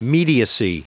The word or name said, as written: mediacy